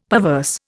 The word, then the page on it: perverse: Indian